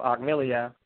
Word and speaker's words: Ahkmeliyah: Oh, Ahkmeliyah.